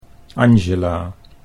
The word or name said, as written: Angela